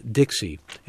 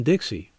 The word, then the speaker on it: Dix: Others who frequented the area read the French word on the $10 bill by pronouncing the X in Dixie. In Dix, excuse me, D-I-X.